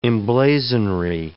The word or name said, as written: emblazonry